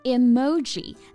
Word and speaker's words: emoji: 那它正确的发音呢，其实是 emoji。 That's right. It's important to put emphasis on the second syllable.